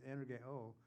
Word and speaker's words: energeo: And the effective, fervent prayer, effective and fervent, it's a verb, energeo is the way to pronounce it, energeo, which means work is taking place.